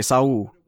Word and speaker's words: Saul: Why are you saying Sawal? Why are you acting like you can't pronounce his name, Beta? – Sorry, Saul.